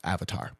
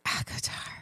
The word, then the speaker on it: ACOTAR: I think it's pronounced Avatar. – No, it's ACOTAR.